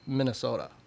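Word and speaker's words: Mini-sota: We launched a lower potency called Minnesota, as in soda. – S-O-D-A? – S-O-D-A. – Ah! – And the way you spell it is M-I-N-I.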